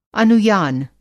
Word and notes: अनुयान: Hindi

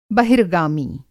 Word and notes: बहिर्गामी: Hindi

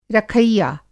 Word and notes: रखैया: Hindi

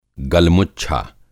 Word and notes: गलमुच्छा: Hindi

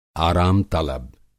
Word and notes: आराम-तलब: Hindi

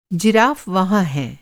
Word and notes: जिराफ़: Hindi

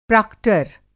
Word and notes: प्राक्टर: Hindi

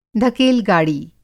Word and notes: धकेल-गाड़ी: دھکیل گاڑی

